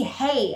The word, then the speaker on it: Hay: My last name used to be Hay, and I had to spell it. – Right. – H-A-Y. – Right. – So there's no S, there's no E. And like my first name, probably 80% of the times people spell it C-L-A-I-R.